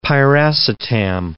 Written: piracetam

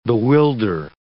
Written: bewilder

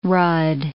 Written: rudd

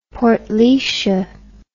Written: Portlaoise

